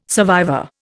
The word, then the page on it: survivor: Indian